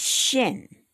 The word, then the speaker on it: shin: R-E-S-H. The next one is shin, like in ship. S-H-I-N.